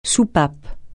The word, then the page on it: soupape: francais